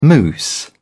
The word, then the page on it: moose: British English